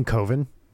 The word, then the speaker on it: coven: Because in the movie, he says it's not coven, it's coven, because coven rhymes with oven and ovens aren't scary.